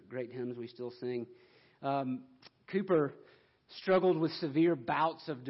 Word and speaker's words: Cowper: William Cooper, his last name is spelled C-O-W-P-E-R, but it's pronounced Cooper.